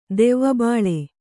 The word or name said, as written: ದೆವ್ವಬಾಳೆ